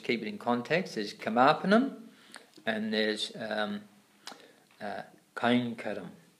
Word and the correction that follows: kainkaryam: Is that, am I saying that wrong? Yeah, you say it. Kainkaryam.